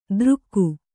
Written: ದೃಕ್ಕು

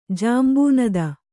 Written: ಜಾಂಬೂನದ

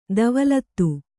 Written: ಧವಲಿತ

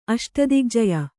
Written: ಅಷ್ಟದಿಗ್ಜಯ

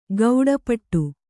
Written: ಗೌಡಪಟ್ಟು

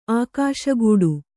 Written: ಆಗಸಗೂಡು

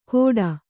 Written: ಕೋಡ

